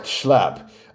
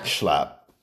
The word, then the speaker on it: Schlapp: Now, I think that's how you say his name. Matt Schlapp. Well, I know how to say his first name is Matt, M-A-T-T, but his last name is spelled S-C-H-L-A-P-P.